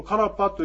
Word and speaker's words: カラッパ: このカラッパというやつもどういうふうなあの文字になるかというと、ローマ字で書きますと、 K-A-R-P-A ですね。 R の発音ですね。 K-A-R-R-P-A。カラッパ。ですから、カーラーパーじゃなくて、カーパーというふうに言うと、これは、あの、発音できます。